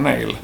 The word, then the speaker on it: Nalle: Um, Nalle är jävligt nice. Om du inte har hört dem. – Ja, oh, fast jag tror att du uttalar dem Nail.